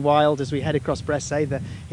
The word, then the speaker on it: Bressay: This particular one plies Lerwick Harbour and goes between Lerwick and the island of Bressay, and in fact, or Bressa as it's sometimes pronounced in Shetland.